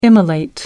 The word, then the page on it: immolate: American English